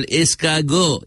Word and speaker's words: escargot: Snails, which they call escargot. Escargot. And it's spelled E-S-C-A-R-G-O-T, not escargot. Escargot. The tea is chowed up.